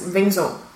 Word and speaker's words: Venzon: So, it's spelled V-E-N-Z-O-N. – Yeah. – Which I would say is, well, as a Brit. – Venzin. – Venzin. But how should it be? – It's Venzin.